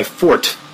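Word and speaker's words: forte: That was my fort, as it's properly pronounced.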